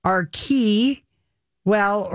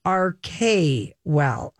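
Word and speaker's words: Archewell: Now, But it is pronounced because they say to us, before Sussex Royal came the idea of arche or arche, the Greek word meaning source of action. So you would pronounce it arche well or arche well are both acceptable.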